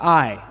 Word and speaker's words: Ai: Ai, or I, as it is pronounced.